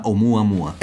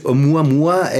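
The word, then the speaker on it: Oumuamua (ʻOumuamua): en hawaiano que se llama Oumuamua? – Eh, tú ves, lo, pronuncias perfectamente. Oumuamua es el mensajero que llega de lejos por primera vez, que corresponde bastante bien a, este.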